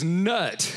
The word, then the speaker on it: Nut: This God's name is Nut. Come on. For real. I don't know if I'm pronouncing it right. Audrey, you have any idea? It's close enough.